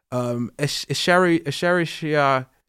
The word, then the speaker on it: Escherichia: Escherichia...